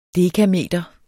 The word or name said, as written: dekameter